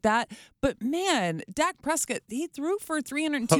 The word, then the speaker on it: Prescott: Prescott.